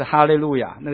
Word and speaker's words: 哈利路亚: 好，你们要赞美耶和华，如果用它原文的发音是什么？就是哈利路亚。那个希伯来圣经它的那个字就是哈利路亚，那哈利路亚是它的发音，你们要赞美耶和华是那个字的意思，哈利路亚的意思就是你们要赞美耶和华。